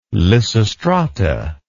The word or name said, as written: lysistrata